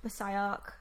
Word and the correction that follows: Bisayan: or is that right? Is that how I said that right? Bisayan.